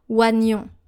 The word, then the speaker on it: oignon: O-I-G-N-O-N. Encore une fois. O-I-G-N-O-N. Now, when you read this word, you are very tempted to say oignon, because we have an O-I and then a G-N, oignon. But this is an exception and we have to pronounce it oignon. Oignon. O-nion.